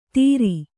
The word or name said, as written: ತೀರಿ